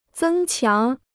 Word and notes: 增强: zēng qiáng

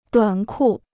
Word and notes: 短裤: duǎn kù

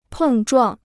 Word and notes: 碰撞: pèng zhuàng

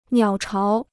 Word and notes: 鸟巢: niǎo cháo

